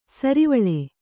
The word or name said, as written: ಸುರ್ವಾಲ್